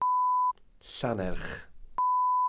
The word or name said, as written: llannerch